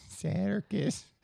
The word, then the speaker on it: Serkis: Serkis.